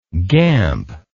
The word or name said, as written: gamp